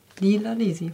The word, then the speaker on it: Lila Lisi: Lila Lisi from Italy.